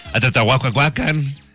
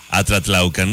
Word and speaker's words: Atlatlaucan: Me da risa que a nivel nacional nadie sabe pronunciar Atlatlaucan. – Así no va. – No. Todas las noticias que yo vi, Atlatlahuacan, Atlatlahuacan, y así, ¿no? – Atlagucan. – Atlagucan. Nadie sabía pronunciar Atlatlaucan.